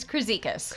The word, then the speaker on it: Kruzikas: It's Kruzikas.